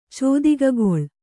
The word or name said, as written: ಚೋದಿಗಗೊಳ್